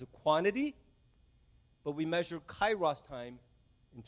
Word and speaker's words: kairos: but it's a word called kairos time. You can spell it K-A-I-R-O-S. It's a Greek word.